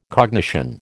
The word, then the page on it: cognition: American English